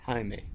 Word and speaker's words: Jaime: So, the correct way to say, you would say inside English, someone will look at it as probably Jaime, but inside Spanish, you say Jaime.